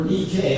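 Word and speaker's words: Nike (Νίκη): And when it's in its noun form, it's referred to Nike, or Nike as the Greeks pronounced it, who was the Greek goddess of victory.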